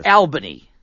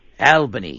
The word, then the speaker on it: Albany: But it's spelled like Albany, New York. – Oh, yes, it is. – But it's pronounced Albany. – Yeah, it's Albany. – Albany.